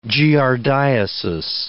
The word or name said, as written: giardiasis